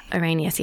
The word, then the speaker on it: Arrhenius: Svante Arrhenius.